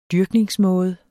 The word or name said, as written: dyrkningsmåde